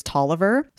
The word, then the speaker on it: Taliaferro: Okay, so his name is spelled Taliaferro. When we were preparing for this, I pronounced the name Taliaferro, and then I got the whole, it's Tolliver.